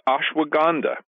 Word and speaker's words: Ashwagandha: Well, let's talk about Ashwagandha. Am I saying that right? Wait, I'm saying that totally wrong. You know, that was right. Ashwagandha. Is that correct? – Yes, Ashwagandha. Right.